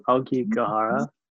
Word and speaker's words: Aokigahara: Yeah, it's called Aokigahara.